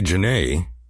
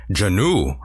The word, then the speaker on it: Jeannot: Anthony Jeannot, or Jeannot, I'm sorry, I pronounced it wrong there, but that is my fault.